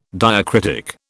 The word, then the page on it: diacritic: British English